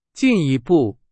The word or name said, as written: 进一步